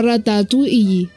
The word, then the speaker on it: ratatouille: Y es sin las L's. Es como ratatouille.